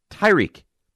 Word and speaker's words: Tyreek: And I was like, all right, listen, rather than go round and round and round, I think you're saying it wrong. It's Tyreek. You're saying Tariq.